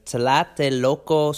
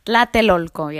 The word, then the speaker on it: Tlatelolco: It's the Tlatelolco Square. I didn't pronounce that right. – Tlatelolco, yeah.